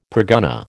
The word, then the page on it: pergunnah: American English